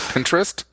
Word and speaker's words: Pinterest: Is that how you pronounce it? Pinterest? – Yes. – Okay, so... – like Dr. Pinter.